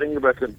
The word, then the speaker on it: Ingebretson: Scott Ingebretson.